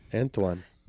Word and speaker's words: Anthuan: A-N-T-H-U-A-N. Antoine? – Antoine. – It sounds like a lisp. – Yeah. – Anthuan. – It's like Barcelona.